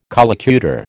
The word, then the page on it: collocutor: American English